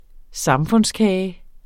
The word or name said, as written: samfundskage